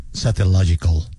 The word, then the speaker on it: Satellogic: Esperemos a ver qué pasa cuando salga el satélite Merlin con Satellogical. Gracias. Dólares en Colombia. ¿Cómo usted lo pronuncia? Satellogic. Me gusta.